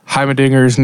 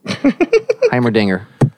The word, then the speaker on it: Heimerdinger: And over this day, I've learned how to pronounce Heimerdinger's name. – Heimerdinger.